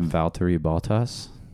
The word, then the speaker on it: Valtteri Bottas: Valtteri Bottas.